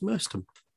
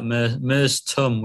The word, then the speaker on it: Merstham: We got there, the pitch was pristine, lovely people at Merstham, we were told. To pronounce it Ollie Mers with a fat tummy. So Merstham, that's how we were told to pronounce Merstham.